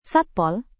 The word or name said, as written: satpol